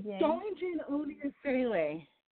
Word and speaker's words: Dawline-Jane Oni-Eseleh: Am I pronouncing Hi. – All of those names correctly? – Darlene Jane Oni Esala. – Oni. Ah, okay.